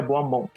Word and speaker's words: Beaumont: Quando ele voltou para Gotham, ele teve um, um, amor que a gente não sabia, que é a Andrea Beaumont, que esse é o, assim que se pronuncia certinho.